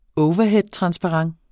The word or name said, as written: overheadtransparent